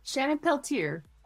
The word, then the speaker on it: Shannon Peltier: We got Shannon Peltier here. – Shannon Peltier. – Oh, that's right.